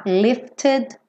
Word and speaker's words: lifted: Eu marquei esse ED porque esse é pronunciado, tá? Lifted me up.